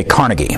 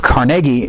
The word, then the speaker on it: Carnegie: Now, usually when we refer to the buildings, we usually say Carnegie, and that's just because of our American pronunciation. I use the Carnegie as his pronunciation because that's apparently the Scottish pronunciation, because all of the audiobooks I've read, when the author speaks of Carnegie, they'll use that pronunciation.